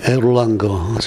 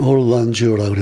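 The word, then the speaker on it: Erlanger: 그래서 아 이분이 독일 독일 사람인 줄 알았어요. 예, 에롤랑거 아세요? 에롤랑거라고 생각을 했는데 예, 근데 이제 부모는 그 유태계 독일 분인데 미국으로 이제 이민 와서 이 얼란주는 이제 샌프란시스코에서 태어났고요. 그러니까 이제 영어식으로 발음하면 얼란주라 그래야 될 것같고그 다음에 이제 Gasser, G-A-S-S-E-R.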